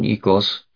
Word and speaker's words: oikos: Literally, this is ekos or oikos if you believe Greek seminary, but I use the modern pronunciation, ekos, house or home, plus nomos, law or rule.